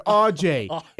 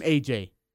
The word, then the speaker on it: AJ: Like, if I say it's AJ, because then they're like, how do you say it? It's a R-G? And I'm like, dude, it's R-J. It's two syllables. R-J. How the fetch you get that off? You know what? I'm AJ.